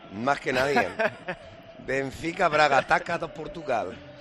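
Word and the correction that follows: Taça: Tasa, sale, no Taca.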